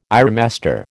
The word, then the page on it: ironmaster: American English